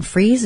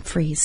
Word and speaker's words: Fries: This is the Sue Freeze Show, and freeze is spelled like fries, and you pronounce it freeze.